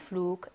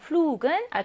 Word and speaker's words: Flug: G가 단어 음절 처음에 있거나 할 경우는 G로 발음을 합니다. 혹시라도 단어 음절 끝에 있다라고 하면 알파벳 G가 크로 발음이 나거든요. 그럼 flug은 알파벳 G가 어디에 있어요? 단어 음절 끝에 있으니까 본래의 발음이 아니라 크 발음을 내는 경우이네요.